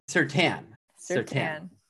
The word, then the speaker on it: Surtain: Surtain. Yeah. Even though there's an I, don't pronounce the I. It's Surtain, not like stain.